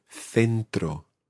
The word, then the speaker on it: centro: So in Spain, it's th as in th. So you have sounds like centro.